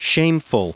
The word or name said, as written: shameful